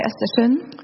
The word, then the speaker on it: Östersund: And last night down in Östersund, if I'm saying it correctly. – Igår kväll I Östersund. – Just, I like the way she said it.